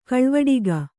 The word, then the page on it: ಗಾಳಿವಟ್ಟೆಗ: kannada